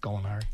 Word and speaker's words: Gallinari: That's how you say it, right? Gallinari. – Yep. Yes.